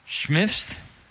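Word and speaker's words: Schimpff's: Schmiff's? How do you... Schmiff's? S-C-H...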